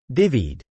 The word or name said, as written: divvied